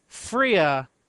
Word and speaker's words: Freya: It's pronounced Freya, yeah.